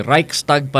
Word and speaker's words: Reichstag: Yung Reichstag pala yon, sorry. Reichstag pala yung pronunciation nung sa Germany na yon, yung Reichstag fire, okay?